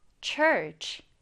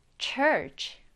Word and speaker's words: church: 이 발음 어려워하는 친구들이 많은데 이렇게 ch가 붙어 있으면 ch, ch 발음이 되잖아요. 그래서 church. church. 이렇게 발음해 주시면 됩니다.